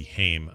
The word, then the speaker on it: Haim: Next one is spelled H-A-I-M. I'm going to say it's, because it can't just be Haim. I'm going to say it's Haim. – Oh, very good. You got that one exactly right.